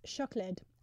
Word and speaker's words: siocled: What I love about Welsh is like chocolate is pronounced chocolid, and it's written S-I-O-C-L-E-D, which is nice.